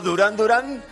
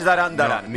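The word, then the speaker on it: Duran Duran: Y luego Durán Durán no es Darán Darán.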